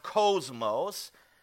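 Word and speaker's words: kosmos (κόσμος): It looks like cosmos, but it's with a K, and it's pronounced kosmos.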